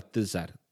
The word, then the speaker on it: Tsar: La palabra zar, si la traducimos del cirílico al, alfabeto que tenemos eh, heredado además de los, de los romanos, se escribiría T-Z-A-R y más o menos se pronunciaría parecido a Tsar, Tsar, Tsar, que es una evolución de César.